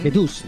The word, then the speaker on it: The Deuce: The Deus, no sé cómo se pronuncia exactamente. – The Deus, Ah, The Deus, The Deus.